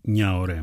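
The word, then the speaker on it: Gnahoré: Πατέρας της, ο περκασιονίστας, ο Μπόνι Νιαωρέ. Με GN γράφεται το Νιαωρέ, έτσι προφέρονται αυτά.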